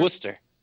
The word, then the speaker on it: Worcester: Now, when I move over past your season with, what is it, Worcester? Is that how you say it? No, Worcester? – Worcester.